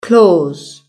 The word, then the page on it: claws: British English